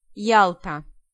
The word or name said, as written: Yalta